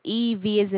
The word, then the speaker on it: Eva: Okay, your first name is spelled E-V as in Victor, A-M? – Yes, Eva, and then M as in Mary.